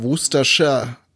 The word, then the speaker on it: Worcestershire: Äh, aber in der Kombination wird's dann eher Worcestershire ausgesprochen. – Mhm. – Also man kann auch Wustersoße sagen, denn äh, ähm, hergestellt wurde die Soße in der Stadt Wuster.